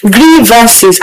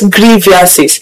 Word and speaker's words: grievances: The first thing first is bringing the word grievances our way, that the word is pronounced grievances and not grievances.